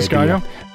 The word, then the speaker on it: Disgaea: Uh, the Disa, sorry, Disgaea.